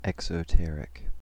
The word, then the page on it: exoteric: American English